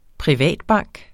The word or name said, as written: privatbank